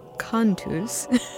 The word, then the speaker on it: Cantus: I said it wrong at the beginning. Cantus.